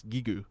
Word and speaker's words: Giygas: Is that how you I, I mean, that's what I've, that's what everyone else pronounces him, Giygas. But then, if you look at the Japanese pronunciation, it's Gigu.